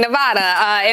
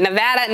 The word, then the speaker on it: Nevada: My apologies to all the people from Nevada for mispronouncing Nevada in our open. Nevada, Nevada, Nevada. My apologies.